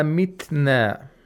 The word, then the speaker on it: mitna: We have a ta with sukun. We have to make sure that the ta is pronounced. If we say aida mitna, mitna, mitna, we have to hear the sound of ta.